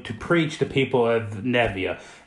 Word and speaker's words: Nineveh: I've heard it pronounced Nineveh. – Nineveh, that's it.